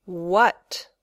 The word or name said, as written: what